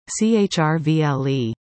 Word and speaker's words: Cherryville: Easy to pronounce, right? Three syllables, Cherryville. Well, not if you're from down south. Two syllables and pretty much leave the vowels out, Chrvle.